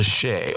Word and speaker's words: Che: Of course, Shay is a bar and a grill here in Abuja. It is spelled C-H-E, so make sure you go there for good vibes only.